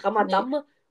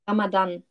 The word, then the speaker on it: Ramadan: ich weiß schon, aber ich habe manchmal so Buchstabendreher oder so, wie zum Beispiel, dass ich immer Nee. – Ramadam und nicht Ramadan sage. – Junge, so witzig. – Aber ich habe es jetzt richtig gesagt, gell? – Ja, Ramadan. – Ja, genau, und ich sage immer Ramadam.